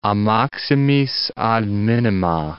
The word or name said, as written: a maximis ad minima